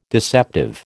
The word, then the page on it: deceptive: American English